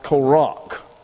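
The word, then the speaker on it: Korach (קרח): But Korach, that is, well, they use a Q, Q-E-R-A-H. And it's pronounced Korach, K-O-R-A-C-H.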